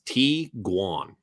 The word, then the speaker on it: Tiguan: How is Tiguan pronounced? This combination of German words is pronounced Tiguan.